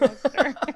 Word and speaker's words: Worcester: It's like, is it Worcester or Worcester? – Oh, it's Worcester. – Or is it Worcester? – Yeah, it's Worcester.